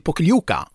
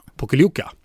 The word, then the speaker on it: Pokljuka: Till Pokljuka. – Pokljuka.